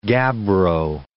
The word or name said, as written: gabbro